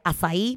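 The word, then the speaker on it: açaí: Está ahora mismo Trayas ahí, que lo pueden encontrar en Instagram como arroba trayacay aunque se pronuncia azaí porque es de allá, del Brasil, y tienen esa letra que nosotros no tenemos acá.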